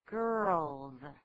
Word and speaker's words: girls: There's an R in there. You must pronounce it girls.